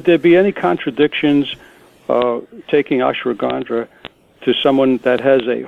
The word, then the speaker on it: ashwagandha: And one of the things that she had mentioned was something called ashwagandha. I'm not really sure how to pronounce ashwagandha. – It. Ashwagandha. Okay.